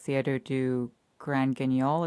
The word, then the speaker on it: Grand Guignol: I'd love to talk more about art and creative endeavors, maybe through talking about your article about the Théâtre du Grand Guignol. Is that how you pronounce it? – Uh-huh, yeah.